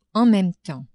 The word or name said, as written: en meme temps